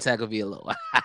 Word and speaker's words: Tagovailoa: Ooh, I said it right. – You did say it right. Wow. – Tua Tagovailoa.